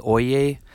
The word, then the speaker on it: Oyez: And a final resource that she had shared that I thought was very interesting was called Oye,